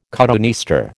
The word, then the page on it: cotoneaster: American English